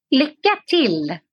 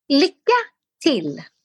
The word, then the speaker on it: Lycka till: How do you say it again? – Lycka till. – Lycka till.